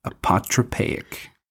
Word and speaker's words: apotropaic: It's your word of the week. Apotropaic. – Apotropaic. – Apotropaic magic. – Will you spell it? – A-P-O-T-R-O-P-A-I-C.